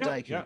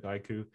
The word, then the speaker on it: Daiku: It is Daiku, correct? Okay. Yeah. I pronounce things wrong all the time, so I'm always checking. – Like Dice and add Ku to it, Daiku with a K-U.